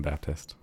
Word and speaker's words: Baptiste: So like all the pro players are calling him Baptist. Baptist. Continue. – Thank you for correcting my pronunciation.